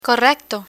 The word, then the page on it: correcto: Spanish